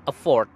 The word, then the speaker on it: afford: E-F-F-O-R-T. Yang berarti usaha atau upaya. Dan yang kedua adalah afford. Okay. Afford. Seperti merek mobil Amerika, Ford. Afford. A-F-F-O-R-D.